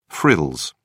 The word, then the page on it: frills: British English